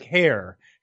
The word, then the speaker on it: Hehir: Uh, I, but having heard it now a million times on podcasts, it's it's like hair, hair. Okay, hair. – I think if you basically just say hair, you're fine.